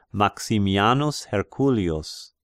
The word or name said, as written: Maximianus Herculius